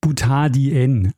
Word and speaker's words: Butadien: Nämlich, ich habe Butadin gesagt. Ich bin mir nicht mehr ganz sicher, was ich wirklich gesagt habe. Es heißt aber natürlich Butadien. Also die Betonung auf dem N und das Die äh, steht für lateinisch 2.